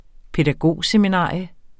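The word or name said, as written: pædagogseminarium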